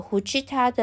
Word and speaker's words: 藤田: 在巴黎，大家称他为“ 夫妇”，因为呢，藤田的日文发音是“胡吉他”，所以呢，就用这个“ 胡吉他” 的“ 呼” 当成是法文的。